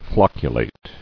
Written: Flocculation

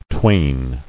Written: Twain

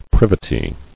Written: Privity

